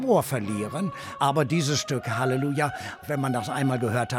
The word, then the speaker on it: Halleluja: Und Halleluja, das war die englische Aussprache, Halleluja, die deutsche, und Alleluja, die italienische. Da sieht es jetzt auch nicht so schrecklich unterschiedlich.